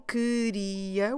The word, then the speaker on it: queria: Q-U E-R-I-A. Queria. So we're not saying queria, we're not reading every syllable and every letter, we're saying in a more relaxed way, queria.